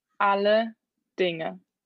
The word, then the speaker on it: Alle Dinge: Alle Dinge. – Alle Dinge. – Alle Dinge. – Dinge. With it, like it's NG. Alle Dinge. – Alle Dinge. – Yeah. – Kind of. – I'm alle Dinge.